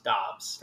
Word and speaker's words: Doubs: Dobbs. – Pronounced Dobbs. – Like, yeah. D-O-B-B-S, but with a U mixed in there. Pronounced Dobbs.